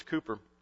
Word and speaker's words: Cowper: There's a great hymn writer many years ago, named William Cowper, spelled like Cowper, but it's Cooper.